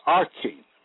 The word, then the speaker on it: archon: The Greek word for prince is archon, pronounced arcane, meaning first in rank or power, chief ruler, magistrate, prince, ruler, although this can imply any demonic principality.